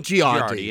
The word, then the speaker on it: Girardi: So one of the attorneys who previously worked with, how do you say, Girardi? – Tom Girardi.